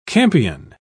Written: campion

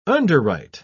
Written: underwrite

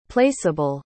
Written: placeable

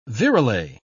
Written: virelay

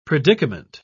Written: predicament

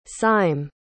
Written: sime

